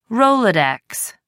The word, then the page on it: rolodex: American English